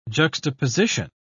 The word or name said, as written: juxtaposition